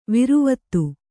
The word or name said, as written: ವಿರುವತ್ತು